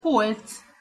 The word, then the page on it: polc: Hungarian